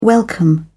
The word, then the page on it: welcome: British English